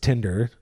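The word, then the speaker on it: TINDR: They're the, I think it's pronounced Tinder, not with the E-R, not with the T-I-N-D-E-R, T-I-N-D-R, and they're tire covers.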